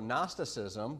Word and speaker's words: Gnosticism: But Gnosticism, if you take G-N-O, the first three letters, and change it to a more English kind of spelling, you get no, K-N-O-W, all right? So instead of a silent G, you got a silent K.